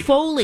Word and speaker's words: Foley: And I didn't know this, but it's called Folly. Foley? – Foley. – Foley. Foley oh, I, am even saying it wrong.